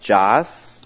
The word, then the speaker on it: Joss: I was born here in the United States with one last name pronounced Joss. And when I moved to Israel, nobody was able to pronounce this name properly because, as you know, there's no J sound in Hebrew.